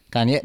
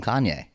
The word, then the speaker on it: Kanye: Kanye. Oh, okay. Kanye, the emphasis is totally different.